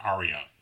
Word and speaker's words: Ariya: It's A-R-I-Y-A, but it's pronounced Aria. – Okay. – So like a musical aria.